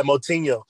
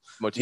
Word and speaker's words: Moutinho: How do you pronounce his last name? – Yeah, Moutinho. – Moutinho, yeah. Okay, that's what I thought.